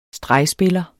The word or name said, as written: STREGSPILLEREN